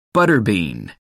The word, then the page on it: butter bean: American English